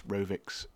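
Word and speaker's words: Rovics: If you go to Jamendo or somewhere like that and type in David Rovix, it's R-O-V-I-C-S, Rovix, that's how you spell it, then you can find it there.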